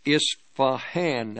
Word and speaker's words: Isfahan: Isfahan, that's how you pronounce that, using drones.